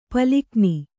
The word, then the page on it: ಪಾಲಘ್ನ: kannada